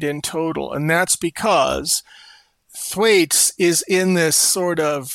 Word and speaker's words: Thwaites: And how do you pronounce that glacier? Thwaites. Thwaites. Thwaites? Okay.